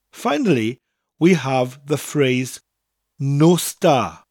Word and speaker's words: nos da: Now, it sounds like I'm saying there are no stars in the sky. Nos da. But it's spelt N-O-S, nos, followed by da.